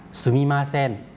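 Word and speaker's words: ซูมิ มา เซ น (すみません): ซูมิมาเซน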